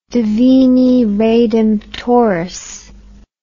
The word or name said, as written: Divini Redemptoris